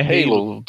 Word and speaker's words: Halo: É Halo. Todo mundo fala Halo.